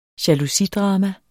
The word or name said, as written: jalousidrama